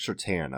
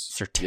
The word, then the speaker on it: Surtain: But I think, to me, Surtain another, is that, how do you pronounce that? Sertain or Sertain? – Patrick Sertain, I believe it is. – Sertain.